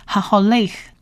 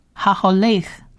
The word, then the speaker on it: haholeh: 希伯兰字母的 huff 是发 h 的音， h 的音，而中间的那两点冒号一样，这个不发音，所以整体发起来就变成 haholeh， haholeh， haholeh，行走，行走。